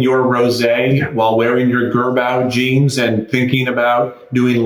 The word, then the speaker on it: Girbaud: Gerbau jeans? – No. – Okay. Well, that's a French word too. – Gerbau? – G-I-R-B-A-U-D. How would you pronounce Gerbau.